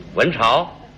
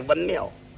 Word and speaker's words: 文庙: 文朝？ – 说错了一个，原来是文庙，他念成文朝了。